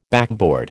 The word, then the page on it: backboard: American English